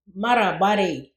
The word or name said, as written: Marra barriyi